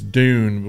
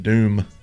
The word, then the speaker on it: dune: We never said it right, though. It's dune, but we said doom.